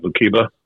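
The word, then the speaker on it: Lookeba: What is that city right above Binger? Lukiba? Lukiba? – Yeah, Lukiba. – Lukiba. – You said it right, man.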